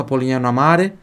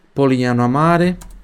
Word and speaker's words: Polignano a Mare: repita em voz alta, Polignano a Mare, Polignano a Mare, Polignano a Mare, o GN tem som de NH, GN tem som de NH, então sempre uma palavra com GN, esse GN você vai ler com som de NH.